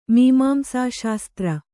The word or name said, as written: ಮೀಮಾಂಸಾಶಾಸ್ತ್ರ